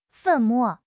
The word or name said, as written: 粉末